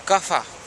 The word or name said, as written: kafa